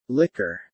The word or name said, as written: liquor